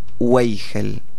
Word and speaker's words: Weigel: En redes sociales, en mi Instagram, salgo como Helmut Weigel, ¿sí? – Sí. – Helmut, mi nombre se escribe con H-H-E-L-M-U-T. – Sí. – W-E-I-G-E-L.